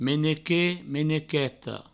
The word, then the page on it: menequet: menequeta; menequeto